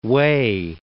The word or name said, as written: Wei